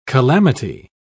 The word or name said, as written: calamity